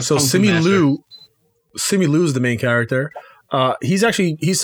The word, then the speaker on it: Simu Liu: Simu Liu. L-I-U. Oh, Simu. Simu Liu. – Spell it for me. – S-I-M-U space L-I-U.